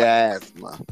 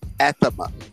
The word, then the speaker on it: asthma: Or like, what's the purpose of the TH in asthma? – Oh, Jesus Christ. – That's if your lungs is good, you can pronounce the TH. That's how they test you. Say asthma. Athema. Tell Mike Tyson to say athema. It's athema.